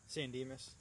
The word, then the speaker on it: San Dimas: I know that the town, I don't know how to say it, but San Dimas? – San Dimas.